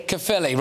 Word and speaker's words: Caerphilly: Is it Caerphilly? – Caerphilly, right, there you go, all right.